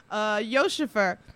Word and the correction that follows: Yusufer: Did I say that right? – Yusufer, yeah.